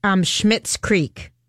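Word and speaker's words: Schitt's Creek: The people who are in Schmidt's Creek, those guys are in it.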